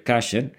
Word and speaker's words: kosher: Ahora, el cartón de leche que tengo ahorita en el refrigerador aquí en casa, que es su casa, tiene el símbolo kosher. A veces se pronuncia kasher.